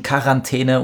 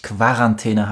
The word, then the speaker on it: Quarantäne: Das Wort entstand in Italien als Quarantäne, dann kam es nach Frankreich, wo es zu Quarantäne wurde und die Deutschen haben bei Fremdwörtern die K-Aussprache übernommen.